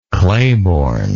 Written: clayborn

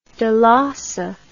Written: dolosse

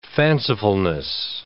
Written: fancifulness

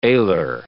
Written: alar

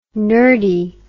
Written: nurdy

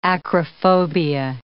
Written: acrophobia

